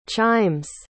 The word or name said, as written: chime's